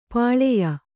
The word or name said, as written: ಪಲ್ಯ